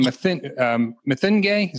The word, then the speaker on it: Mathenge: And then Solomon Mathenge, is that how you say his name? – That's right.